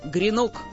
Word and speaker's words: гренок: Там было слово «гренок». – Что-что? – «Гренок». Один гренок, два гренка, они гренки. То есть слово это было мужского рода, да и ударение было на последнем слоге, а вовсе не на первом, к чему все мы привыкли.